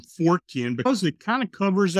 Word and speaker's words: Fortean: Fortean refers to Charles Fort.